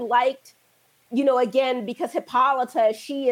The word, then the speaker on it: Hippolyta: And okay, so wait, and another thing that got me is the pronunciation of Hippolyta's name. I was like, Hippolyta? I was like, I'm pretty sure it's Hippolyta.